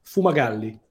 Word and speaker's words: Fumagalli: Fumagalli.